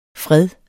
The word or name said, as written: fred